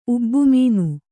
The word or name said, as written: ಉಬ್ಬುಮೀನು